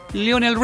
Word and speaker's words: Lionel: Porque en español se escribe Lionel, Lionel Richie.